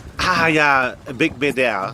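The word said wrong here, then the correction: Beigbeder: Big Beder. – Beige Beder. – Beige Beder. – Ja, genau. Auch toll.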